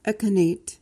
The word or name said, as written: echinate